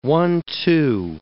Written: one-two

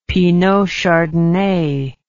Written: Pinot Chardonnay

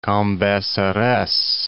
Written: Cambaceres

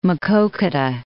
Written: Maquoketa

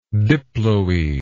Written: diploetic

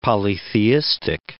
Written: polytheistic

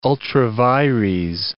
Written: ultra vires

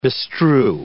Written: bestrew

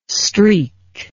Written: streek

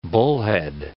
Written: Bullhead City

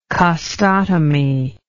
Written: costotomy

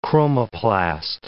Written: chromoplast

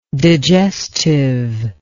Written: digestiveness

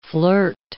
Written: flirt

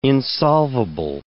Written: insolvable

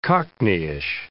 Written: cockneyish

